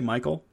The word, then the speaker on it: Michael: So uh, since I'm explaining that anyway, you might you might just be better off saying Michael. I don't know. Experiment with it. Tell me what you find. Um, pronounced Michael or Michael, depending, and it literally translates to "he who is like God."